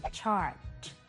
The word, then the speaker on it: charge: บางครั้งคำว่า chart อาจสร้างความสับสนกับคำว่า charge ที่เรามักจะทับศัพท์คำนี้นะคะซึ่งหมายถึงการเติมพลังแบตเตอรี่หรือการเรียกเก็บค่าบริการนะคะจุดสังเกตก็คือเสียงตอนคำลงท้ายของทั้งสองคำนี้ซึ่งมีความแตกต่างกันนั่นเองนะคะเรามาลองฟังการออกเสียงของสองคำนี้กันอีกครั้งนึงค่ะคำแรกคือ chart ส่วนอีกคำหนึ่งคือ charge พอจะเห็นความแตกต่างในการออกเสียงของทั้งสองคำนี้แล้วนะคะทีนี้มาดูการใช้คำว่า charge ที่แปลว่าเรียกเก็บค่าบริการในประโยคกันค่ะตัวอย่างเช่น Honey, do you realize we get charged almost $100 each month from your subscriptions of websites?